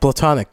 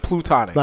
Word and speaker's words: platonic: Platonic. – Platonic. – Yeah, platonic. – Instead of plutonic. – Not plutonic. Plutonic sounds like you're trying to make a nuclear bomb.